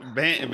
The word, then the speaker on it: Bendana: Bendana? – Yes, sir.